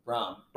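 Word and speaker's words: Brohm: I didn't realize Purdue's coach, Baum, how do you say it, Baum? – Brom.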